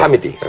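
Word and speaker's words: comedy: 코미디. 아. 발음 좋게 할까요? 네. 카미디. 어, 알겠습니다. 예. 카미디네요.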